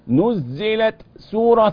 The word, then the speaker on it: نزلت: نزلت سو افصل التاء عن السين نزلت هكذا التاء اللي فيها شدة وفيها همس نزلت سورة تتنطق ازاي ها